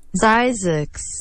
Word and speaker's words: Zzyzx: OK, ça s'écrit Z-Z-Y-Z-X. – Mais voyons donc. – Fait que Zix. – Zizix. – Ah, Zizix. – Zizix. – OK, Z-Z-Y-Z-X, Zizix.